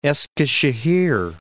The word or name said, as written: Eskisehir